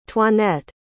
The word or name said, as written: toinette